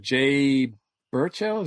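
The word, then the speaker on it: Jay Baruchel: Mark Maron, you know, Jimmy Fallon, Rainn Wilson, Jay Burchell, is that right? Am I pronouncing that right? – Yep.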